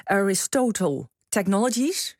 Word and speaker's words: Aristotle Technologies: Aristotle Technologies, zeg ik het goed? – Ja, dat zeg je goed.